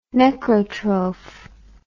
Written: necrotroph

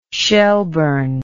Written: Shelburne

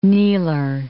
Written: kneeler

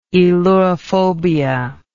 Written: aelurophobia

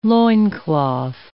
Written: loincloth

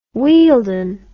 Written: wealden

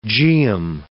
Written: geum

